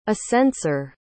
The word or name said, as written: ascenseur